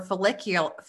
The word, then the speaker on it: follicular: Number one, for follicular, am I saying that right? – Yep.